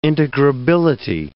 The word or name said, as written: integrability